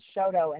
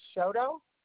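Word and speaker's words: Chouteau: It's 2600 Shoto, and that's what I told her.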